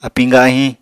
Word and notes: apiñ gahiñ: Apatani

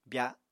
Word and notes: bya: apatani

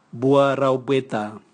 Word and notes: bua raubweta: Raga

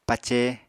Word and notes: pace: Apatani

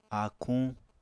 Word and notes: áakuñ: apatani

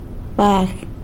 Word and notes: bag: sanlucasquiavini

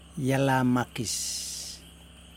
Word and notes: jelamakis: Yokoim

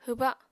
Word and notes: hwba: apatani

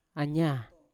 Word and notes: anya: Yawa

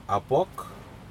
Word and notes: aPok: Aneityum

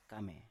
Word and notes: kame: Biak

